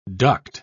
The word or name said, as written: duct